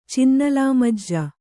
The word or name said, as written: ಚಿನ್ನಲಾಮಜ್ಜ